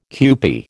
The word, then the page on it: kewpie: American English